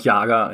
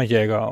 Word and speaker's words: Jäger: War das richtig ausgesprochen? – Jäger. Wahrscheinlich, oder? Ne? Ich sage immer noch Jager einfach, wegen Jagertee und so. – Die Kollegen von dem Unternehmen, die sprechen ihre Firma Jäger aus.